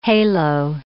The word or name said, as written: halo